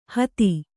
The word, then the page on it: ಹಾದಿ: Kannada